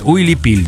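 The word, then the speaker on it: huipil: Правильно говорить, вот Уилипиль, да.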